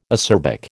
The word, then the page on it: acerbic: American English